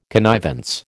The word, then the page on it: connivance: American English